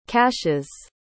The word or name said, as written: caches